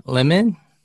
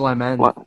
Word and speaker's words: Liman: It was directed by Doug Liman. Is that how you say his name? – I think it's Lyman. – Lyman.